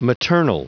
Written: maternal

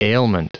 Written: ailment

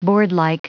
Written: boardlike

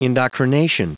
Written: indoctrination

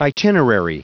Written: itinerary

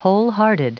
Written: wholehearted

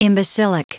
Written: imbecilic

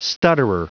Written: stutterer

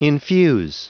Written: infuse

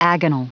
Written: agonal

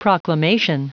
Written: proclamation

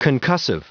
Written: concussive

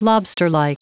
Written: lobsterlike

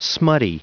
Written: smutty

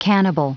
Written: cannibal

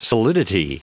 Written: solidity

